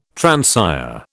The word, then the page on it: transire: British English